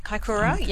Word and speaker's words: Kaikoura: Kaikoura. – Kaikoura, yes.